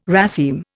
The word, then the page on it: raceme: Indian